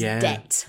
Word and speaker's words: debt: The word, I think, with a missing B where students make mistakes with pronunciation and spelling is debt. – Mm. – Like, I'm in debt. I have borrowed money and I can't pay it back. That is spelt D-E-B-T, but we don't pronounce the B.